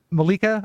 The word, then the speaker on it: Malika: Am I saying her name right? Malika, I guess it is. – Sounds right.